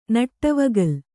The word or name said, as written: ನಡೆವಗಲ್